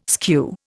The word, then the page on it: skew: Indian